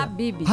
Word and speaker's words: Habib's: Habib's, como é que pronuncia? – Habib's. – Habib's.